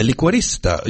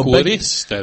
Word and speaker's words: liquorista: What we call a liquoriste. – A liquorista. You know, liquorista. There we go. My first mispronunciation. Well, say that again properly. – Liquorista.